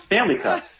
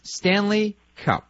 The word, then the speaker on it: Stanley Cup: It's Stanley Cup. It's the Stanley Cup, not Stanley Cup. You're putting the accent on the wrong syllable.